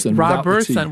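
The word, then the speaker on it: Roberson: Did I say his name right? – Robertson, without the T.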